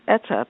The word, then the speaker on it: Etta: They ask, is my first name Etta or Etta? And so I patiently explain that it's Etta because there are two T's, which makes the letter E short.